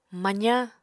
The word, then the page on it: mazañ: Apatani